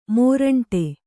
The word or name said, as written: ಮುರುಂಟು